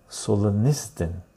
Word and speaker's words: Solzhenitsyn: A Key Watches is the, give me the right pronunciation. Sol Zen Nizd In. Sol Zen Nizd In. Sol Zen Nizd In. Solonistin.